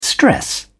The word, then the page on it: stress: British English